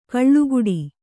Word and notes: ಕಳ್ಳುಗುಡಿ: kaḷḷuguḍi

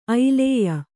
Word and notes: ಐಲೇಯ: ailēya

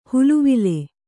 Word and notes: ಹಳವಳ: haḷavaḷa

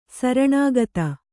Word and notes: ಶರಣಾಗತ: śaraṇāgata

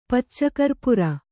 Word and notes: ಪಚ್ಚಕರ್ಪುರ: Kannada

